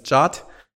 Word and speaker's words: JWT: Und dann hat man, dann spricht man von so einem genannten JWT oder JAT. unter den, unter den Nerds heißt es JAT.